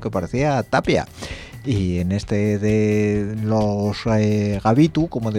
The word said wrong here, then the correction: Gatibu: digo yo, Gatibu, sale uno que, no sé, podría parecer lastres mismamente, guapamente.